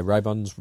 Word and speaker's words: Ribbons: So I think, like, Dave Ribbons, however you say his name, right? – Yeah, Ribbons, Ribbons.